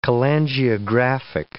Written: cholangiographic

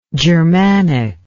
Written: germanically

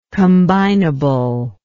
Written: combinableness

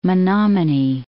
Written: Menomonee Falls

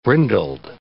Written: brindled

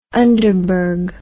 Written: underberg

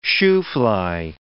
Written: shoofly

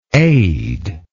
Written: aidless

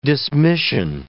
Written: dismission

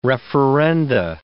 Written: referenda

